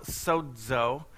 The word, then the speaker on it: sozo: And we discover that by looking at the Greek word sozo, or if you want to really pronounce it properly, it's called sozo, is the true Greek way of saying that.